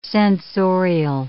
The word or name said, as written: censorial